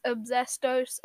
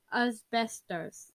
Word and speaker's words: asbestos: It's asbestos, a poisonous thing. – Sorry, I pronounced it wrong. It's asbestos.